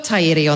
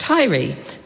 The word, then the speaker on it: Tairi: It is frequently mispronounced Tairi, which is an island off the coast of Scotland. Many of us whakapapa to Scotland down our way, so it's easy to see how this happened. However, I can assure this House that Marcus Lush was correct in his pronunciation. Our place is Tairi, which speaks to our awa, the fourth longest river in New Zealand.